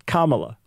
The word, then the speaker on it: Kamala: It's like, okay, you can hate her and not vote for her, but pronounce her name right, Kamala.